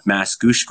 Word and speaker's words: Mascouche: A couple days ago, Mascouche, Quebec, was it? Am I saying it right? – Yeah.